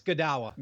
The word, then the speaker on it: Godawa: No, it's Godawa.